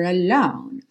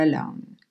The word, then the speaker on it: alone: Mas o Elton John canta mais up here alone. Ok? Então, rocket man burning out his fuse up here alone. Alone. Não pronuncie a letra E no final.